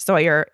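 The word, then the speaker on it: Sóller: It's on the island of Mallorca, which is an island off of Spain, in this particular place called Sawyer, S-O-L-L-E-R, pronounced Sawyer in an American accent. I don't think it's actually pronounced that way for anybody else, but that's how I say it.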